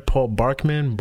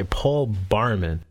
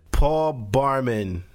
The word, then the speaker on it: Barman: Paul Barkman? Barman? Okay, Paul Barman. – Paul Barman. At least I know how to say his name. Everybody else seems to be fucking up his name lately. They're calling like Paul Barkman, fucking Charles.